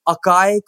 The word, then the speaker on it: Akaay: And apparently it's Akaay Kohli, like Clint McKay.